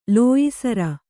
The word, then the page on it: ಲೋಯಿಸರ: lōyi sara